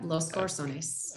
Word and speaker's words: Los Corazones: Your Copa uh, brand that you referenced earlier, Los Corazones. Is that is that right? Los Corazones. Yep, Los Corazones.